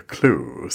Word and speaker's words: Clew: And we're talking about an app called the Clue, C-L-E-W. Is that the right pronunciation of the app, Paul? – Yes. Yeah, absolutely.